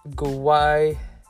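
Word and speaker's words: guaiol: This is the terpene guayol, pronounced guayol. I said it right, guayol,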